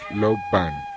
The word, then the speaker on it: Low Ban: Una se escribe L-O y se lee Low, Low significa pez, sol. Y la otra se escribe Bun y se lee Ban, es cáscara de mar.